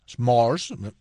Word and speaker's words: Morse: Un día como hoy del año 1844, desde el Capitolio de Washington a la ciudad de Baltimore, el inventor Samuel Morse, Morse, dicho como yo pronuncio en inglés, Samuel Morse, emitió el primer mensaje telegráfico.